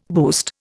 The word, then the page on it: boost: Indian